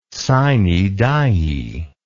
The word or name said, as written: sine die